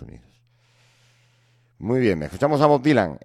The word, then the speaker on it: Bob Dylan: Bob Dylan. A ver, señor catedrático, pronuncia conmigo. Bob Dylan. Bob Dylan, eso es.